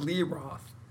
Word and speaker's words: Lee Roth: You see Paul Stanley, Steven Tyler, David LaRoth. They've all lost quite a bit. – I love when you always say his name. Say that name again. – David LaRoth? – That's not. It's David Lee Roth.